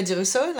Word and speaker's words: DeRusso: And our guest today is Julia DeRusso, if I pronounce it correctly. – Yes, you do. – Julia, will you introduce yourself? – Yes, I'm Julia DeRusso, like you said.